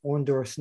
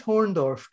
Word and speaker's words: Orndorff: I always used to tease Bruno that he never could pronounce Orndorff's name. Every time I hear Orndorff, Bruno would go, you know, this Horndorff guy, he put an H, you know, this Horndorff, you know, he's got the build, and this Horndorff guy.